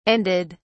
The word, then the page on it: ended: British English